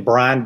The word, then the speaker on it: Brian: Look, just go to Amazon and type in Brian Dodd, and Brian is spelled with an I, and with my Southern accent, nobody can understand my last name.